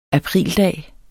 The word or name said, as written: aprildag